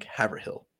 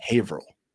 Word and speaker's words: Haverhill: It's kind of like when I sold in Massachusetts, there's a town, it's spelled H-A-V-E-R, Haver, and then H-I-L-L, one word. So you would think Haverhill. No. Haverhill. You're like, all right, that makes no sense.